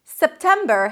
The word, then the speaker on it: September: September has three syllables. The first syllable is sep, tem, right? Make sure that you raise your pitch a little bit for the primary stress, septem, and then br, a b and an r, br, br.